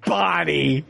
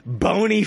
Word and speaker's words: Bonnie: How to pronounce Bonnie? All these years I've been watching One Day at a Time, and I've been saying Boney Franklin all these years.